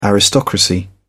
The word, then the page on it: aristocracy: British English